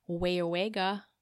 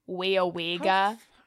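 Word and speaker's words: Weyauwega: You're saying it wrong. It's Wayawega. – Wayawega. – Wayawega. – Wayawega. – You have to say it very Wisconsin-y.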